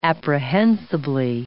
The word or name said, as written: apprehensibly